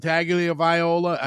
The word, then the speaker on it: Tagovailoa: And I don't pronounce an N in Tagovailoa. I think I'm saying it wrong on the back end, though, because there is no N.